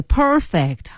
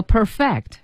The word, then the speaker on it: perfect: 对， perfect 和 perfect 是不同的发音了。